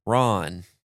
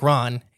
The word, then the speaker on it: Rhawn: Is it R-A-H-N? – No, close. R-H-A-W-N. Like Sean. – Oh. – R-H-A-W-N. Like Sean. – Ron. That's cool. – That's the only reason I'm pronouncing it like Ron, is because it's the word Sean with an R.